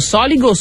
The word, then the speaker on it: Soligo: Però ci sono quei posti che dice dove andrà l'accento? Soligo, sì.